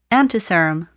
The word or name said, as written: antiserum